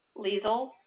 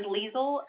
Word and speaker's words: Liesl: Liesl Ignoffo. – Wait, how do you spell that? L-E? – first name is Liesl, L-I-E-S-L. – Last name? – Mm-hmm. Last name Ignoffo. Starts with an I, G-N-O-F-F-O.